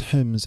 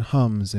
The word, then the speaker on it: Homs: There were two men visiting the Syrian city of Homs, pronounced Hims in Arabic, but Homs in English, H-O-M-S.